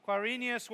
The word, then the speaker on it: Quirinius: Quirinius was governor of Syria, and all went to be registered, each to his own town.